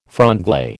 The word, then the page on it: franglais: American English